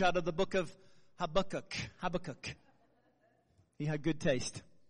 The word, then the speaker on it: Habakkuk: Is that how Americans say it? Habakkuk. Yeah, Habakkuk.